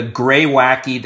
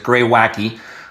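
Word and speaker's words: Greywacke: So, my favorite, however, was the Grey Wacky. That's GREYWACK. It looks like it'd be pronounced Grey Wack, but it's Grey Wacky.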